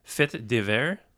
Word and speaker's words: Fait divers: Fait divers.